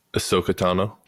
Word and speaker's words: Ahsoka: Ahsoka? – Ahsoka Tano.